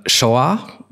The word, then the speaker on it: Saor: Sch wie, wie spricht man sie aus? Bitte erschlagt mich. Ähm, Shoah. S-A-O-R mhm. aus ah, genau, Schottland.